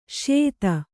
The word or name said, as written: ಸ್ಯೂತಿ